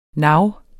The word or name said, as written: navene